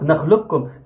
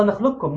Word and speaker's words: نخلقكم: فمنتظر الكلام، ننطق نخلقكم إن شاء الله، نخلقكم. نعم نعم، من غير قاف خالص، حضرتك شيل القاف وقرأ إن شاء الله.